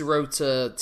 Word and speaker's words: Tsuruta: And it's, in case you're curious, it's Tsuruta, Tenryu,